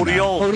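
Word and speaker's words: Oriol: Uriol. – Que es U. Es la O que se pronuncia U, como Josep, que es José.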